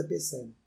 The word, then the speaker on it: Psé: Instagram, Psé diminuta, Minuta, se escreve com P mudo, Psé, mas é Psé, não é?